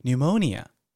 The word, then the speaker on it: pneumonia: 好，比如说你们可能都知道的一个单字是 pneumonia。 Pneumonia 是肺炎的意思，哦，这个 P 不发音。